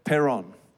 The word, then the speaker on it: Perón: Did I say it right? Yes. Perón, the president at the time,